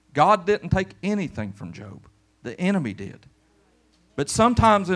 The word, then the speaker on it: Job: It's kind of like Job said in his story in the Bible, the book of Job. I like it because it's spelled like Job, and a lot of people don't even know how to say it, and they'll say Job, the book of Job.